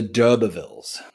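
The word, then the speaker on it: d'Urbervilles: Tess of the Dubervilles.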